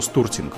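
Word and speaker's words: Storting: Ну, по-русски стортинг, скорее всего, стуртинг называется по-норвежски, потому что они О как У произносят чаще всего.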